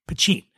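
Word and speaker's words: poitín: And when I say Poutine, it's spelled P-O-I-T-I-N, Poutine.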